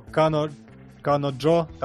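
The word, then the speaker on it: Kanojo: Если у вас победит Домикана или моя домашняя девушка, или доместик, но Кано Кано Джо, так оно правильно читается, или лучшая горящая мусорка по гигуку.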